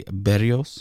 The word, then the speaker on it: Berrios: We got James Paxton going up against Jose Berrios.